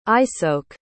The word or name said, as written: isoke